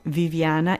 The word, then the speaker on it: Viviana: Viviana, it's V-I-V-I-A-N-A.